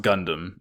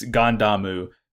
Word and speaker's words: Gundam: Miorine is going to be the head of this whole Arm Incorporated thing, but it's pronounced Gundam. It's it, it's Gandamu the same way in uh, as you say the Gundam in Japanese.